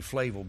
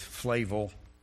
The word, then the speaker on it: Flavel: John Flavel, and I know the name's pronunciation is debated, but I agree with one scholar who says it's probably Flavel because an Englishman would not have a French pronunciation of that name because when he lived, the French and the English were at war. So I go with Flavel.